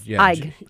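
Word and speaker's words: Eig: And then Jonathan Egg, that's how you pronounce his last name? – Yes, Egg.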